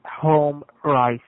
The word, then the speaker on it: Home Rise: I'm calling from Home Rise, sir. So what do we... – Sorry, I didn't quite catch the name. Can you say that again? – It's Home Rise, sir.